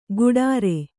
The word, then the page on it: ಗುಡಾರೆ: guḍāre